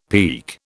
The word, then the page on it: peek: British English